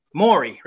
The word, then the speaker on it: Morey: Nice thing about him at Philly, you know, Doc has had a chance, I'm sure, and the GM Morey. Am I saying that right? The dude from Morey. Houston. – Morey, right? Yeah. Thank you.